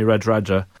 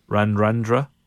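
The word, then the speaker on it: Radradra: So on Tuesday morning, Bristol announced that they had signed the Fijian superstar Semi Radradja. Or that, I've got the pronunciation wrong. All the D's are N's, aren't they? All the R's are N's. Randrandra.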